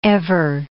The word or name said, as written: ever